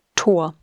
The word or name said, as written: Tor